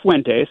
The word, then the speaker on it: Fuentes: Is it Fuentes? Is that the last name? Am I pronouncing? – Yeah, it's Fuentes.